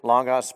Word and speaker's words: logos: And if we were to take the original Greek word, perhaps many of us have already heard the Greek word logos or logos, depending on how you pronounce it. Logos, proper pronunciation.